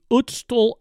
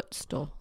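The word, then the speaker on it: Ötztal: the Ootstal Alps. It's spelled O-T-Z-T-A-L. – Ootstal.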